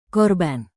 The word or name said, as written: korban